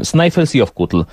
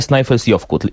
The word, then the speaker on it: Snæfellsjökull: Snæfellsjökull.